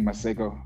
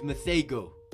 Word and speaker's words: Masego: That's not how you pronounce it. Don't be that guy. – That guy's went to South Africa enough to pronounce it like that, bro. – Nah, dog. He's Masego.